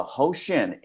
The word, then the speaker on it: Hoshin: The Hoshin, H-O-S-H-I-N, rhymes with ocean.